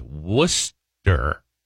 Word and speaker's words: Worcester: And then in bright yellow lettering at the bottom of the script here, it says, pronouncer, Worcester is pronounced Worcester.